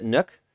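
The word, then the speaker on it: NUC: Because I think Intel actually pronounces it Nook.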